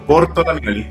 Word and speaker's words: Bortolameolli: Sí, es, es difícil mi apellido. Bortolameoli.